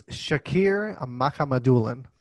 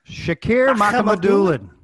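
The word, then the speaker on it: Shakir Mukhamadullin: It's Shakir Makhamadulin. Shakir Makhamadulin. – Makhamadulin. – You stole it. I was going to say it, and I was going to say it right.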